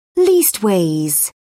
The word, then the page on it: leastways: British English